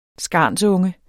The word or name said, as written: skarnsunge